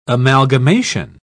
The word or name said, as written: amalgamation